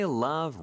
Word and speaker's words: love: 자, 일단 love, L 발음이 중요해요. 왜 중요하다고 하느냐? 리얼처럼 발음하면 rub로 들릴 수가 있거든요.